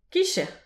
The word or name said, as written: kizhier